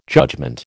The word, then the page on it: judgement: American English